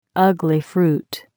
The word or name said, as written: ugli fruit